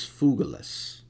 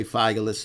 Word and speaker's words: Phygellus: But here he mentions two men in particular, Phygellus, and the correct pronunciation is Phygellus. And Phygellus, you could say Phygellus if you want, his name means a little fugitive.